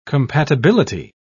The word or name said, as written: compatibility